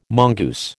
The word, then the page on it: mongoose: American English